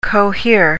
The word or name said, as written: cohere